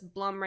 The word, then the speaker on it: Blum: So this is one where it's like, when I did research, people said it was Blum rhymes with Plum.